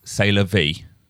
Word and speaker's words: C'est la vie: But no, you say the correct pronunciation, but we aren't talking about French here. We are talking about 普通せ英語。So my pronunciation is correct. C'est la vie.